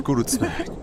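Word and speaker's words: Kreuzberg: خوینزبرگ. اینطوری تلفظ میشه. – نه گروتزبرگ.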